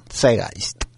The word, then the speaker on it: Zeitgeist: yo intento pronunciarlo en alemán, Zeitgeist,